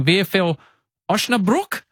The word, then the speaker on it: Osnabrück: May 12th, 2024, you have to fix my pronunciation here, Jacko, you're playing VfL Osnabrück? – Yeah, pretty good.